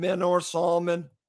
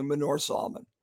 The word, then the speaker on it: Manor Solomon: Start Bobby Dekker, Dover Reed on the right and Manor Solomon on the left. And I said it wrong. It's actually Manor Solomon.